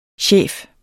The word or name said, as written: chef